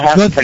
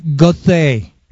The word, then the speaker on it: Goethe: Goethe. – It is called Goethe.